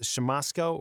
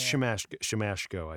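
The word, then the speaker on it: Siemaszko: Now, is it pronounced Shamosko or Shamashko? I believe it is Shamashko. I did just verify. – It is Shamashko.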